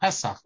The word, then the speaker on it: Pesach: My kids hate it because they're all Pesach, Pesach, which I guess is how it should be pronounced.